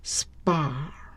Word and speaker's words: sprach: sprach, sprach，那个 ch 在德语发音应该是 h。